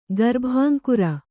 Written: ಗರ್ಭಾಂಕುರ

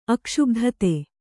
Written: ಅಕ್ಷುಬ್ಧತೆ

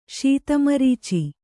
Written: ಶೀತಮರೀಚಿ

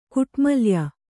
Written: ಕುಟ್ಮಲ್ಯ

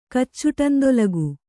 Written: ಕಚ್ಚುಟಂದೊಲಗು

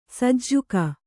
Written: ಸಜ್ಜಕ

